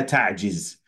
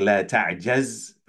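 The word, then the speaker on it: ta'jiz (تعجز): Wasta'in billah wa la ta'jiz. You'll hear a lot of people say this hadith, they say la ta'jiz, but that is an incorrect pronunciation according to many of the scholars of hadith.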